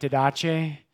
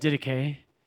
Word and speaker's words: Didache: It looks like Didache, but you pronounce it Didache.